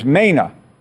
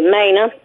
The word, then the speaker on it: Mana: So it's spelled. It's it's not ma, ma, it's Mena. – Oh, well, yeah, with the long A, Mena, Mena yeah, Eloise.